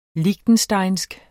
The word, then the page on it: liechtensteinsk: ˈligdənˌsdɑjnsg; ˈliçdən-; -ˌɕdɑjnsg